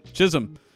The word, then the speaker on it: Chisholm: How do you pronounce it? – Chisholm.